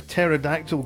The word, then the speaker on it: pterodactyl: Why can't you hear a pterodactyl go to the bathroom? – Oh, gosh, I wish we were playing the other game. – Uh-huh. – Why? – Because the P is silent!